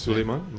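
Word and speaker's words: Suleyman: Now in September of this year, Mustafa Suleiman, I think is how you pronounce his last Suleiman.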